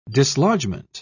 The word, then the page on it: dislodgement: British English